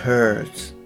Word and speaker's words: hers: それで、えっと、この、えー、 hers の h もですね、しっかり発音されているので、 and that's hers っていう感じで言ってるんですけれども、この h もですね、やっぱり、えー、省略されやすいです。